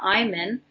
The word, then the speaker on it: Eiman: And my name, Laura Iman, my last name is spelled E-I-M-A-N.